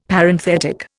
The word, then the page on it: Parenthetic: Indian